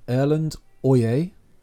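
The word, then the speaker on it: Erlend Øye: Kings of Convenience are a folk duo from Norway made up of Erland Oye and Erik Glambeck Bøe. Is that how you would have pronounced it? – Yes. Good.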